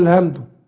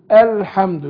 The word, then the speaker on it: الحمد: ما ينفعش اقول الهمدو، قول الحمد.